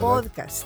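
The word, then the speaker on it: pódcast: pero con una salvedad, lleva tilde en la O. – Sí, ¿verdad? – Podcast.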